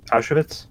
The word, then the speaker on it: Auschwitz: I can never pronounce that word. – Auschwitz? – Yes. Thank you.